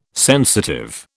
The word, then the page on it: sensitive: British English